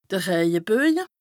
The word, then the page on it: de Rëjeböje: Bas-Rhin; Strasbourg